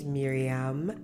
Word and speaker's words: Miriam: But to those who don't know me, my name is Miriam, M-I-R-I-A-M. I feel the need to spell that out because everybody always gets my name wrong, either the spelling or the pronunciation. So I just have to kind of put it out there. It's Miriam.